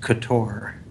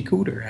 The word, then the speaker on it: Couture: I got yelled at because I didn't know the thing is pronounced couture. Not that you said juicy. I always thought it was Juicy Cooter.